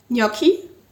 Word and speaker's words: gnocchi: We had gnocchi. That's how you pronounce it. Don't listen to Mindy.